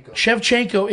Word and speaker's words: Shevchenko: So, Shevchenko, that's how you say her name, right? – Shevchenko is the better fighter in this fight, in my opinion.